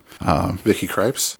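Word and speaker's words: Krieps: Vicky Kripes.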